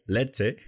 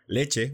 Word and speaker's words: leche: I can say it leche, which is usually considered to be stilted, you know, leche, which would be the standard pronunciation. And then we have leche, which is a different type of pronunciation.